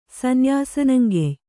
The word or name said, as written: ಸನ್ಯಾಸನಂಗೆಯ್